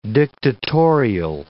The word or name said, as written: dictatorial